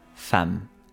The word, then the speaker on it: femme: su pronunciación correcta según el francés original es femme, la femme fatal.